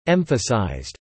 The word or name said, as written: emphasised